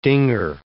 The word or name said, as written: dinger